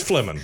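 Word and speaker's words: Flehmen: But it's actually, it's the Fleming. How do you pronounce it? – It's Fleming.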